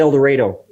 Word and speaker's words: El Dorado: Pronounced El Dorado when you're in Arkansas. Rhymes with tornado.